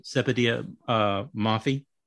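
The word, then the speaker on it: Sepideh Moafi: How do you pronounce her name? – Sepetiah Maffey.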